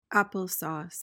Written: applesauce

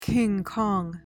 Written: King Kong

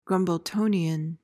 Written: grumbletonian

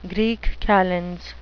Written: greek calends

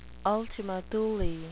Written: ultima Thule

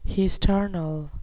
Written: hesternal

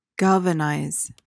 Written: galvanize

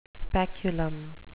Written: speculum

